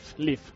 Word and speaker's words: Live: Perdón, no Live, Live. – Eso es. – Live Another Day. – Se escribe igual y por eso puede llevar a la confusión.